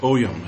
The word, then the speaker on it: Ogham: The petroglyph was written in Ogham, or Oyum, as it's also sometimes pronounced, which is an ancient written language used in Ireland and other places.